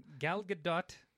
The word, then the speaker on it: Gal Gadot: Gal Gadot. That's how you say her name, by the way.